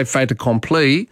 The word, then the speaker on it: fait accompli: In French, if the last letter in the word is a consonant and the start of the next word is a vowel, you do pronounce it. So if you just said fait by itself, it's F-A-I-T, fait, you don't pronounce the T.